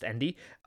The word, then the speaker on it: Ende: I hope it's pronounced Ende. And probably translated because it's originally written in German. And yeah, that would be correct, yes.